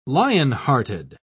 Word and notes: lionhearted: British English

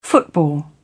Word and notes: football: British English

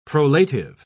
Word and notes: prolative: British English

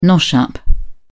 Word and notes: nosh-up: British English

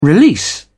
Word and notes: release: British English

